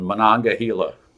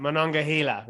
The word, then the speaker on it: Monongahela: You got them all but the last one, Monongahela. – Right, Monongahela, right, yes.